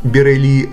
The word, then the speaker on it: Бирели: Бирели, так будет правильно говорить по-французски, родился в семье кочевых цыган Мануши в городе Эльзас. Его отец был музыкантом, а сам Бирели взял гитару в руки в возрасте 4 лет – по признанию Лагрена, огромное влияние на становление его мастерства оказала музыка Джанго Рейнхарда.